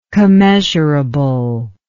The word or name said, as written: commeasurable